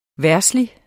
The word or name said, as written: VERDSLIGE